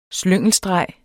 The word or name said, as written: SLYNGELSTREGERNES